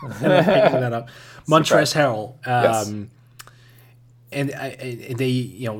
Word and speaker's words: Montrezl: It's actually Montrezl Harrell, I figure out. I've always pronounced the L. – Yeah. – That's an adjustment to me. Because on broadcast sometimes, announcers actually do the same mistake as I do and pronounce L.